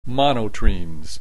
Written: monotremes

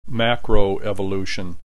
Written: macroevolution

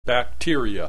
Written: bacteria

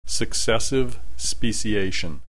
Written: successive speciation

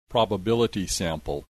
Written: probability sample

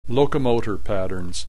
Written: locomotor patterns

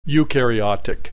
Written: eukaryotic

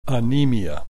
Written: anemia

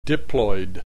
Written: diploid

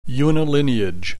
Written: unilineage

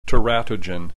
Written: teratogen

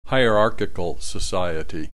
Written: hierarchical society

